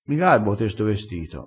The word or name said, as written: garba